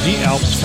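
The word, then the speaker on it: Dielps: D-Elps, it's spelled D-I-E-Elps, pronounced D-Elps, Common Denominator from their CD called More Important Things.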